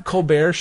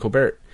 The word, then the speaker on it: Colbert: Because I was like, well, you have Colbert's show, which this is funny. Seth Meyers is the one that she had in the movie, but the Stephen Colbert show, late show with Stephen Colbert, Colbert.